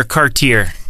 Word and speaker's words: Cartier: So do you say Cartier or Cartier? – I say Cartier. – Yeah, we do as well. So, yep. Well, I'm glad that you pronounce it right.